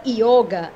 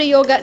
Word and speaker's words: yoga: Já a palavra yoga ou yoga, na verdade, tanto faz como a gente pronuncia. A palavra yoga tem origem no sânscrito.